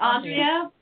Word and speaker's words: Andrea: It's Andrea, but I've been called all of them, so I don't actually object.